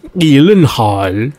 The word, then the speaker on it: Gyllenhaal: Or actually, as it should probably be pronounced, as it's a Swedish name, Gyllenhaal.